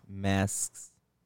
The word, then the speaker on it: masks: Do you know how to say the plural word of mask? – Masks. – Okay, one of you hit me with the X. – Masks. – You hit it right.